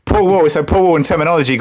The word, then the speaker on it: Paul Wall: Paul Wall. You got to say it properly. Paul Wall.